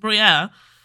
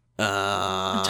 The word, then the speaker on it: Boreanaz: Because you got Danny Trejo, you got David how do you say his Boreanaz? – Name? – Boreanaz. – Boreanaz.